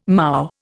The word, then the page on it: moue: Indian